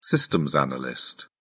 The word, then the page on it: systems analyst: British English